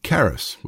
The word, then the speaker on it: charis: So, for instance, the Greek word that we often translate for grace is charis, with a hard K, C-H-A-R-I-S, charis.